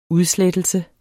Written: udslettelse

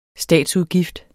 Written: statsudgift

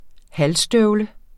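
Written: HALVSTØVLES

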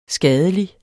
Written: skadelig

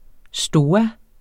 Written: stoa

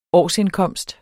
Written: årsindkomst

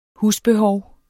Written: husbehov

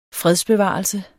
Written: fredsbevarelse

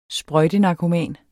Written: sprøjtenarkoman

